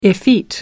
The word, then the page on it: effete: American English